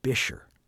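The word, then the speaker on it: Buescher: It employed a young Gus Bisher, not like it's spelled.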